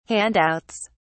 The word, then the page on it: handouts: British English